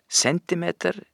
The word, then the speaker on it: centimeter: Og det samme med centimeter, det er ingen grunn til den franskinspirerte uttalen centimeter.